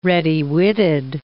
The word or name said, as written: ready-witted